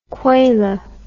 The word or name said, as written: kwela